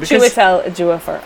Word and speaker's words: Chiwetel Ejiofor: And then also, the other major star, and I hope I pronounce this right, but Chiwetel Ejiofor. Yes. Oh, my word. He is so awesome. – Chiwetel Ejiofor.